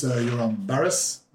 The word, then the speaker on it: Joram Barrez: So my name is Joram Barrez.